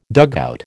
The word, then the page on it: dugout: American English